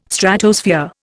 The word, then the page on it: Stratosphere: Indian